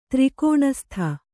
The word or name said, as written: ತ್ರಿಕೋಣಸ್ಥ